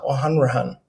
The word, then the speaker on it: O'Hanrahan: and his name is Peter O'Hanrahan.